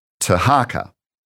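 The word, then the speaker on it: Tirhakah: There's another king later on, and he's mentioned in 2 Kings 19:9, and his name is Tiharca. And it's spelt with T-I-R-H in the Bible, in our English translations, but we believe that is Tiharca of the 25th dynasty.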